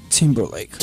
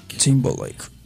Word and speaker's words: Timberlake: E todo mundo sabe, quem acorrer acesso à internet e vê tudo, que hoje saiu um GIF que o Justin Timberlake. Falei certo? – Justin Timberlake. – Timberlake.